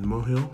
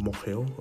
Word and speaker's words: Mohill: So my nan was from Republic of Ireland, County Leitrim in Mohill. I believe that's the correct pronunciation, Mohill.